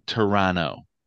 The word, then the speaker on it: Toronto: For our Canadian fans out there, and my Canadian friends in the world, it's Toronto. The T is silent. Canadians don't pronounce the T at the end. – Toronto? – It's Toronto.